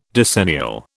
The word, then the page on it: decennial: British English